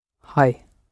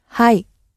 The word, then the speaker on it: はい: はい。 – はい。